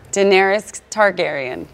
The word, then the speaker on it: Daenerys Targaryen: Daenerys Targaryen.